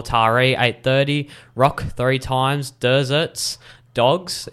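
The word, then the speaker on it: Derzert Dogz: I'm pretty sure it's Desert Dogs, but it's spelled very uniquely with a D-E-R-Z-E-R-T. And Dogs is, of course, D-O-G-Z.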